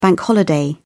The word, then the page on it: bank holiday: British English